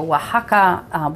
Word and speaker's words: Oaxaca: I love Oaxaca. It's spelt O-A-X-A-C-A. It's crazy, isn't it? So Oaxaca.